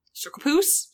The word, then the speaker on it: Circarpous: Circapus. – It's spelled C-I-R-C-A-R-P-O-U-S.